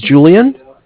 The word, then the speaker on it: Julian: My name is Julian. It's okay, Julian. – It's spelled J-U-L-I-A-N.